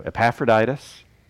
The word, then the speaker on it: Epaphroditus: So Epaphroditus. All right, so does anybody know anybody named Epaphroditus? That hard pass, right? Like not going to be showing up. All right, so apparently Epaphroditus was a stunningly common name at this point in history.